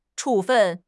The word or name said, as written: 处分